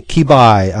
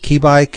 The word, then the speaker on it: Qibai: Kibai, on the forums. I think that's how you pronounce it. Kibai, Q-I-B-A-I. Is it Kibai? – I don't know.